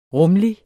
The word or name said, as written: rumlig